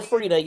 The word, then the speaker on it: Frieda: And it's still Frieda, y'all. – Fred with an I-A. The I is silent.